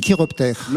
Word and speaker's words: chiroptères: Les chiroptères. – L'ordre des chiroptères, ça s'écrit C-H-I. Et ça se prononce bien chiroptère.